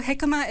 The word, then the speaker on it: jicama: It's spelled J-I-C-A-M-A. Yes. What in the world is jicama? – Yes, so it's pronounced jicama because it comes from Mexico and Central America. – Oh, sure. – So okay, that makes sense. – Has the J is an H.